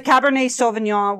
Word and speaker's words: Cabernet Sauvignon: Cabernet Sauvignon. So, did I say it wrong? – No, I think you said it perfectly.